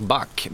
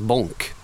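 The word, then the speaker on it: Bąk: Det där, jag hade en spelare 2002 som heter Jerzyk Bak, B-A-K, polsk spelare. Och polacken hörde av sig, han uttalas Bonk, B-O-N-K ungefär. – Mm. – Men det står ju B-A-K, var går gränsen?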